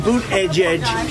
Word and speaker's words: Buttigieg: And Boot Edge Edge should have been here already. – Boot Edge Edge. – That's how you say it, but he said it so funny.